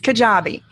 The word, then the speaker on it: Kajabi: So we're going to look at some of the fully hosted, and then I apologize about the pronunciation of this, Kajabi. We're looking at Kajabi first. Did I do a reasonable job pronouncing that? – You did. It's Kajabi. – Kajabi.